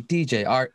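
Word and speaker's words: DJ Artt: DJ Art, with two T's for a double dose of that tink tink. The D is silent, so it's just Jart.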